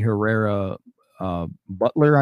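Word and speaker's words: Herrera Beutler: Dan Newhouse and Jamie Herrera Butler, I think is how she pronounced her last name.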